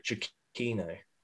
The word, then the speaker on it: Giacchino: Michael Giacchini or something? – That's it. – I've actually got one of the Incredibles songs on my gym playlist. I think it's actually pronounced Michael Giacchino.